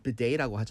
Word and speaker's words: bidet: 이렇게 비데 그 영어 발음 bidet라고 하잖아요.